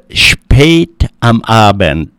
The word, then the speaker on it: spät am Abend: Well, number four is spät am Abend.